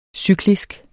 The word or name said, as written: cyklisk